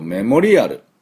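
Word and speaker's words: memorial: 次、あとはですね、こういう記念式に欠かせない単語っていうと、メモリアル。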